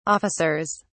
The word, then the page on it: officers: British English